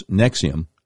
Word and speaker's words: NXIVM: He's the founder of a group, NXIVM, which they pronounce Nexium, which is described as a multi-level marketing company and sex cult based near Albany, New York.